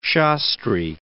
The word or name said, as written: Shastri